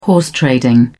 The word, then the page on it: horse trading: British English